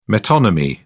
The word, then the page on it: metonymy: British English